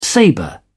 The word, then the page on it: sabre: British English